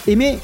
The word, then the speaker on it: Aimé: Aimé, qui est une chanteuse japonaise, a un nom qui s'écrit comme le verbe aimer en français et qui se prononce de la même façon en japonais.